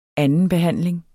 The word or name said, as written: andenbehandling